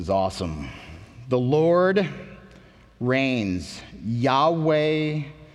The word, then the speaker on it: Yahweh: If you've ever heard the name Jehovah, that is where the name comes from, that idea. Most scholars today understand how to pronounce that as Yahweh.